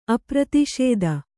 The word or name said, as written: ಅಪ್ರತಿಷೇದ